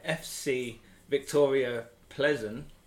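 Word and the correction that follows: Plzeň: travelled to Roma. – Victoria Pilsen. Pilsen.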